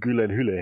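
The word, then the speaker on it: Gyllenhaal: Prince of Persia damals, wo sie nämlich die ganzen anderen äh, Co-Stars von Jake, Gillen Hülley, gefragt haben, wie sein Nachname eigentlich wirklich ausgesprochen wird. Und sie alle sich den, den Mund kaputt geredet haben und dann äh, er nachher einfach meinte, it's Gülen Hülley, hey.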